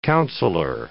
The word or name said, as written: counsellor